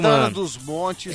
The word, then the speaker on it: Santana dos Montes: Água, água cristalina de Santana dos Montes, não é isso mesmo, Santana dos Montes.